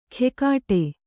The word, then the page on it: ಖೆಕಾಟೆ: khekāṭe